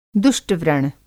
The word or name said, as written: दुष्ट-व्रण